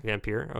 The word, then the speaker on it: Vampyr: Vampyr, okay.